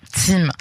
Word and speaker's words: ZIM: Merci tout d'abord d'être avec nous ce soir pour parler de la vente de cette compagnie maritime privée qui est, alors on dit ZIM, c'est quoi l'appellation? – Je crois qu'il prononce ZIM. – ZIM.